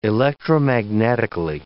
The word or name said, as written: electromagnetically